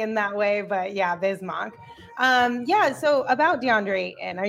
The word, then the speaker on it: Bismack: It always throws you, though, because it's spelled Mac, M-A-C-K. Yeah. So I feel like that's why people always get confused, because when they're looking at the name, they're going to pronounce it kind of like that. But it is Bismack.